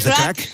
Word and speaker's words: craic: Con lo cual ahí podremos, decirte a ti y al resto de componentes de The Corrs, what's the craic? Creo que lo he dicho bien. – What's the crack? – Eso.